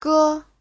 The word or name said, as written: ge5